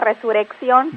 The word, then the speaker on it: resurrección: Otra, resurrección. Resurrección. ¿Cómo se dice correctamente? – Lo que pasa es que a veces no hacemos el sonido fuerte, ajá, la mayoría dice resurrección.